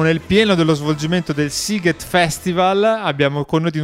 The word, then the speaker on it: Sziget: E tra pochissimo parliamo di Sziget, si pronuncia così,